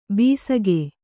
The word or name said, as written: ಬಿಸುಗೆ